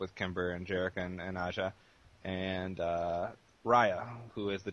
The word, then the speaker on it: Aja: We had, let me see if I say this right, Aja. Mm-hmm. Is that how you say it? – Yeah, Aja.